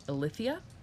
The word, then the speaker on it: Eileithyia: Uh, but she was uh, in labor with Apollo longer because after Artemis was born, Hera forbade her daughter, Eileithyia.